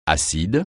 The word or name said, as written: acide